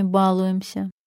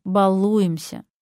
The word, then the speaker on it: балуемся: Эх, жаль, что так говорил. Потому что нормативное ударение в этом слове «балуемся». Предвижу ваш гнев. Простите, но этот литературный вариант зафиксирован орфоэпическими словарями.